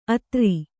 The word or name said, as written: ಅದುರು